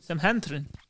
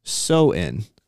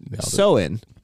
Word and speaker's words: Samhain: S-A-M-H-A-I-N. – Samhantrin. – What? Kazuntite. S-A-M-H-A-I-N is pronounced... – Halloween. – So-in. So-in, because the Celtic language is weird.